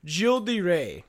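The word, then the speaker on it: Gilles de Rais: So I would pronounce this guy's name as Gildas de Reyes, but they call him Gil de Rey.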